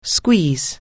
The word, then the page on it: squeeze: anglais